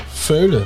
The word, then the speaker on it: veulen: Hoe spreek je dit uit? Veulen? Veulen, ja, ja, mij Veulen, ja.